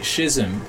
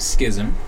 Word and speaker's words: schism: they tend to mark the Great Schism, or the Great Schism, depending on your pronunciation, of 1054, I think, when the Roman Catholic Church went off and the Orthodox Church went off the other way.